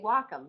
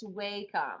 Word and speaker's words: Wacom: If you go to the Wacom site, people who are on their site say they say Wacom, but like my students will be like, it's Wacom. I'm like, let's go to the site and watch the video and see how they pronounce their thing.